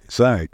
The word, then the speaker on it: ASIAC: Tell us how to pronounce it. – ASIAC. – It's that.